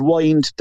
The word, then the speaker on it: Wind: He that song, Dark Horse, they pronounce it Wind, Dark Horse on the Wind.